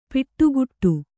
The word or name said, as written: ಪಡಿಕಟ್ಟು